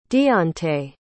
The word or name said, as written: deontae